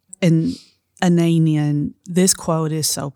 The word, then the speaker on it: Anaïs Nin: Is it Anaïs Nin. Yes. Am I saying that right? Yes, you I, are.